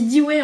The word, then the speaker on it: Dewey: Historiquement, et on y reviendra, le système de classement le plus commun est celui dénommé classification décimale de donc D-E-W-E-Y. Donc on dit Dewey en français, mais en fait c'est un, une personne anglo-saxonne et en, anglais on prononce Dewey.